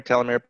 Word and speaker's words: telomere: So Dr. Park wrote a book about, and I mispronounced it earlier in a previous recording, that the telomere, is it telomere? – Yeah, telomere, potato, potato, either way.